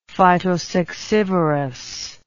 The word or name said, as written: phytosuccivorous